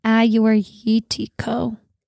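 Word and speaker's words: Agiorgitiko: Ayurvitiko. It's actually spelled like Agiortichiago is how it's spelled. But, it's Greek. So it's honestly going to be hilarious when we go to Greece because when I was in college.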